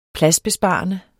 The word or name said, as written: pladsbesparende